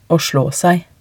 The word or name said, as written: å slå seg